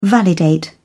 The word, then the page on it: validate: British English